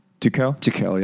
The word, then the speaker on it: Tuchel: Tuchel, yeah.